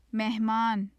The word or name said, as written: mehman